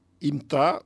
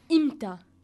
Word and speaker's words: imta: Imta, c'est plus simple. – Oui, imta. – Qui s'écrit de la manière I-M-T-A.